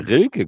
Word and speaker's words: Rilke: Von Rieke deutscher Klassikerroman. Rilke gleich liest er.